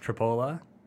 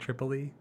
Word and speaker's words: Tripoli: Tripoli, actually pronounced Tripola. That's another West City, yes. – Hmm. – It looks like Tripoli, pronounced Tripola.